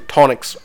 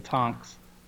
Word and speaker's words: Tonks: I didn't know if I wanted to bring that one up, but since everyone's making fun of me for pronouncing Tonix wrong, Tonix. See, now you're messing me up. – Oh, just move on. You're going to drive me crazy. – There's no I or E in Tonks.